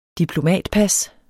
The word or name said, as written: diplomatpas